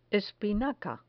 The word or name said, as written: espinaca